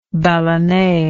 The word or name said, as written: ballonne